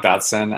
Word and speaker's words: Dodson: I'm Rob Dodson.